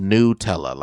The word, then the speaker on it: Nutella: Another revealed, you know, that it's pronounced Nutella, like N-E-W, Nutella.